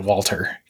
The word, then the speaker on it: Walther: but that's a Walter or Walther. Yeah. Mm-hmm. They always pronounced it Walter because they're British and they don't do foreign affectations.